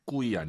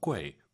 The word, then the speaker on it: 贵: 꾸이 아니고 UI 사이에 에 넣어서 꾀, 꾀 시 꾀 이렇게 발음하시고요.